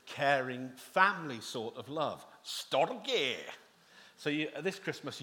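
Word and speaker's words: storge (στοργή): It sounds sort of Scottish. It's storge. That's how you pronounce it, storge. Not with a Scottish accent, but probably with a Greek accent.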